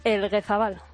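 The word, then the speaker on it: Elgezabal: Y en el Alcorcón hoy hay presentaciones, se presentan el centrocampista Víctor Pérez y el defensa Unai Elguezabal. – ¿Cómo has dicho que es el apellido? – Elguezabal. – Me las pronuncia muy bien.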